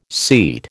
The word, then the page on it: seat: American English